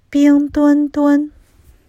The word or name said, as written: Bing Dwen Dwen